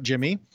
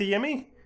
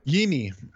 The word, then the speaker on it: Yimi: And it's Yimi now, apparently, not Jimmy. – We're back to Yimi? – Yimi.